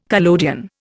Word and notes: collodion: Indian

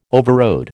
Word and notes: overrode: American English

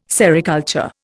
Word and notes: sericulture: Indian

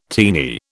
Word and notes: teeny: British English

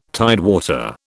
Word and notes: tidewater: British English

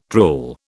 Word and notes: drool: British English

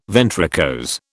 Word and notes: ventricose: British English

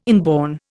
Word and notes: inborn: Indian

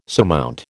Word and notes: surmount: American English

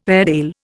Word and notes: Airedale: Indian

